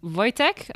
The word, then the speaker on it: Wojtek: So this bear that we're talking about is called Corporal Wojtek, I think is the way you'd pronounce it, or something like that. – Exactly. Perfect pronunciation.